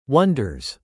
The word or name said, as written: wonders